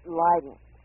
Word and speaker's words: Leiden: Did I say that correctly? – Leiden is the pronunciation.